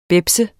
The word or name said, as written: bebs